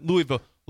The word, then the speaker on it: Louisville: I'm going to make sure not Louisville. – Louisville. – I said that once. He did not like the way I said Louisville is how you say that.